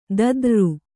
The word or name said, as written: ದದ್ರ್ರು